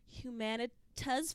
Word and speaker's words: Humanitas: Humanitas Fellow, maybe? Yes, I pronounced that right.